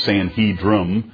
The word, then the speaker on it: Sanhedrin: It's the Sanhedrin. I always pronounce that with an N at the end rather than an M. It's Sanhedrin.